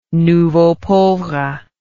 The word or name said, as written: nouveau pauvre